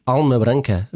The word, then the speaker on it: Alma Branca: On vient de l'écouter justement ce titre sur ton album Alma Branca. Je le dis bien aussi, ça va? – Très bien, impeccable.